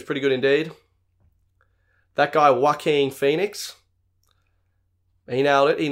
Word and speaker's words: Joaquin: I said, excuse me, it's pronounced Joaquin.